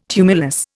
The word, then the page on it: tumulus: Indian